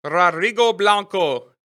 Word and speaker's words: Rodrigo Blanco: I'm your host, and today I have Rodrigo Blanco. Did I say that right? I didn't roll my R's perfectly. – More or less, but it works.